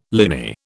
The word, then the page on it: liny: British English